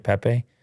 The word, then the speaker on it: Pepe: Is that how you say it, Pepe? – Yes.